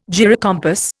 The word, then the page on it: gyrocompass: Indian